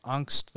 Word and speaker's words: Angst: Next up, we have 1983's Angst, which if you're wondering why I'm pronouncing Angst like that, it's because this is a German, well, Austrian, but, you know, German language movie. And it's pronounced Angst there. It means fear.